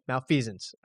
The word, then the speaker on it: malfeasance: Actually, I'm not exactly sure how to pronounce that word. – Malfeasance. – Okay. Malfeasance. All right.